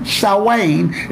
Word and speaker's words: Samhain: It's spelled S-A-M-H-E-I-N. Samhain is how it's pronounced.